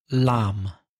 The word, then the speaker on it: lamb: W-O-M-B. – And the next one, Cordero. – Lamb. L-A-M, silent B at the end.